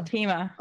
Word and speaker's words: Alteima: As it uh, Almia, Alteima? – Alteima. – Alteima. Thank you, Alteima.